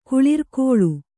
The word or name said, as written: ಕುಳಿರ್ಕೊಳ್